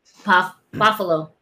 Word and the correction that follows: Poffo: Isn't it Poffalo? Poffalo. Might be saying it wrong. – Poffo, right? Yeah. Hey, what's Macho Man's last name? Poffo, right? – Poffo. Oh, I just added an extra.